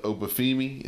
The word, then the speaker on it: Oba Femi: Uh, how do you say this dude's name? Oba Obafemi? Is that how you say it? – Oba Femi. – Uh, yeah. yeah.